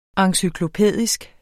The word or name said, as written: encyklopædisk